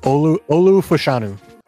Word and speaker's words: Fashanu: You had, I don't even know how to say this, but Fashanu? – Olu Fashanu.